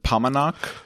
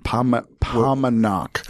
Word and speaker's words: Paumanok: So, by the way, so we pronounce it Pamanok. – Yeah. – It rhymes with Montauk, we believe. – Pamanok.